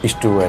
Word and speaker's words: ishtu: But there they call it ishtu. – Oh. – Yes, it's pronounced ishtu and roti or whatever.